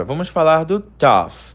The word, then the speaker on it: tough: A pronúncia do tough não tem nada a ver com dull. Você não precisa colocar a língua entre os dentes.